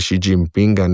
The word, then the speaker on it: Xi Jinpinga: Izpostavil pa bi, da vse eno izgovarjamo načeloma slovenskimi glasovi. Tako da ne morem jaz zdaj z tega Xi Jinpinga neki mehčani š delati, ker mi bo to oteževalo samo izgovorjavo.